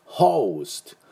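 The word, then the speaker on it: host: だからホストじゃなくホーストホーストが正しい発音になります。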